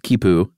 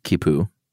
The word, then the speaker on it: khipu: Is it, I believe it's pronounced Kipu. – Yeah. – K-H-I-P-U. – Or Q-U-I-P-U. – Right. Which would also be pronounced Kipu.